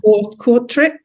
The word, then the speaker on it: Kortrijk: Kortrijk, is it? – Yeah.